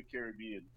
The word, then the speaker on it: Caribbean: And so I want to know, because we've been having this debate about the pronunciation. So, I'm saying Pirates of the Caribbean, and then I know some people are saying Pirates of the Caribbean. So, well, let's just start with you setting the record straight. Like, how do you say it? – that's a good question. You know what? I always go with Pirates of the Caribbean. – All right. – That's how we say it.